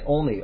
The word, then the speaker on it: Olney: That's how you say it, Olney, O-L-N-E-Y, Olney, whatever.